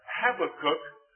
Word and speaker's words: Habakkuk: If you pronounce it Habakkuk, that's okay, but Habakkuk.